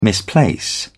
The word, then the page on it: misplace: British English